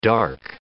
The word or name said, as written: dark